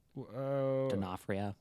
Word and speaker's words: D'Onofrio: D'Onofrio. – Yeah. No, Vincent D'Onofrio.